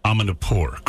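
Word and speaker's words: Amanpour: And all he did was send me back his latest star appearance with Christine Amana. I can't say her name on CNN. The very proper reporter. Amanapour. Christine Amanapour.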